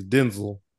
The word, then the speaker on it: Denzel: He did an interview and he told people, you know, people have been saying it wrong. It's not Denzel. It was like, it's Denzel.